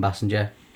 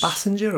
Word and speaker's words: Basinger: Basinger or Basinger? – Oh, good shout. We're awful with these pronunciations. – And nobody know I've heard people use both as well. – Yeah. I think you're right. I think it's Basinger. – I don't know. I'd always Basinger. Say Basinger. But it's double S, like a bass.